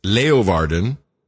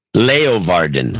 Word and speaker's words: Leeuwarden: I can't remember what it was, but I think it was Lou Warden. – Here it comes. – I can't pronounce it. – Here it comes. It's Leeuwarden. – Leeuwarden. – Very good.